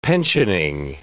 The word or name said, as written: pensioning